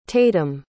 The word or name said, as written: tatum